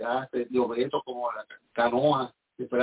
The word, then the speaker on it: canoa: y ellos le llaman canoa, con la última sílaba lengua, luenga, larga, esa canoa.